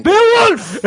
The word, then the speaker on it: Beowulf: Não, a pronúncia, não, a pronúncia certa é essa. Como é que não, fala, não. Então? Como é, é quem? É Beowulf!